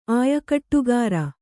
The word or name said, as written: ಆಯಕಟ್ಟುಗಾರ